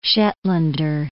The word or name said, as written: Shetlander